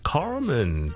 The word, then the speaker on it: Carmen: Today we're going to talk about the pronunciation of 一个英文名 Carmen, C-A-R-M-E-N。咁咧，第一件事，如果你系讲美式英文嘅，一定是要卷你的了， Carmen 小叔卷得比较差，不好意思啊 Carmen。